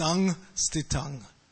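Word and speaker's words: saṃsthitam: So when you see the dot over the M, it's pronounced like an NG. So it's Sangstitang.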